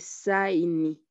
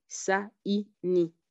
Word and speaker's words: saini: Par exemple, et saini, saini, saini. Il y a A et I qui se suivent et qui se prononcent différemment en Kiswahili. Et saini signifie signature en français.